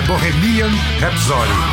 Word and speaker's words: Bohemian Rhapsody: E como eu disse, eu falei, eu anunciei errado o nome da primeira música. É Bohemian, né? Bohemian Rhapsody. Agora a pronúncia está certíssima.